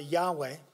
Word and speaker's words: Yahweh: They simply had the consonants, and it would equate to YHWH, and we pronounce it Yahweh. In some of the older translations of the Bible, it is referred to as Jehovah. But more correctly, it should be Yahweh.